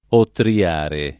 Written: ottriare

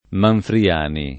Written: Manfriani

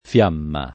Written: fiamma

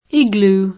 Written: iglù

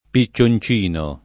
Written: piccioncino

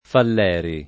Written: Falleri

